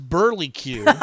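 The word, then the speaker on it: Burlesque: and this is Burly Q. thanks to Andy from Night of the Living Podcast to let us know about the perfect pronunciation, because I was pronouncing it wrong the whole time.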